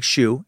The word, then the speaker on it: SHU: It's pronounced like SHU, as in check for shoes, by the way. S-H-U, yeah.